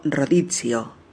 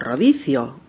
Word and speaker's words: rodizio: Este tipo de restaurante es lo que conocemos en España como rodizio o rodicio, como lo quieras pronunciar.